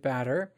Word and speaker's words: Badre: How Our Brains Get Things Done by David Badre. Um, and yes, that is how you pronounce his name.